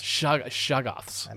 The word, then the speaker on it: Shoggoths: Shoggoths. – I believe that's the pronunciation.